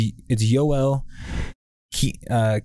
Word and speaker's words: Kiviranta: I put it in that sheet I sent you earlier, how to pronounce it. It's Yoel Kiviranta is that guy's name.